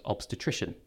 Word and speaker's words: obstetrician: where Cathy trained and worked as a consultant obstetrician. Have I pronounced that correctly? – Yes, well, full marks.